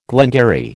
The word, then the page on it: glengarry: American English